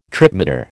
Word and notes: tripmeter: American English

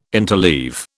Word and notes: interleave: British English

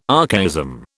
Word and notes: archaism: British English

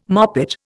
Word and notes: moppet: Indian

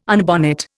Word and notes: unbonnet: Indian

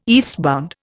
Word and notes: eastbound: Indian

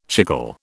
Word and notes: chicle: British English

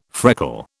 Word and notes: freckle: British English

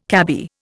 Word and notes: cabby: Indian

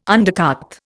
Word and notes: undercart: Indian

